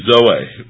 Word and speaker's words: Zoe: Z-O-E, Zoe, or Zoe is how we might pronounce it.